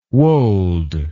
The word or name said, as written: woald